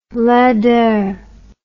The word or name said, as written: linder